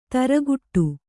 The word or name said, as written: ಥರಗುಟ್ಟು